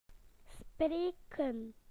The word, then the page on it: to speak: British English